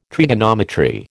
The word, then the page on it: trigonometry: American English